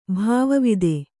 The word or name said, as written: ಭಾವವಿದೆ